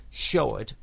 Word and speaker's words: Shoard: So we're going to catch up with this lady, and her name is Catherine Sheward. And that's spelled Shoard.